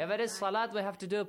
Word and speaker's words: salat: It will be pronounced as salat. Wow, on the top of wow, there is dagger alif. Not salawat.